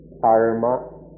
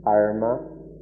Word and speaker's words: dharma: The form of the word that's most well known in the West is the Sanskrit form, which is should be pronounced dharma, dharma, this is a little bit different than the Pali form.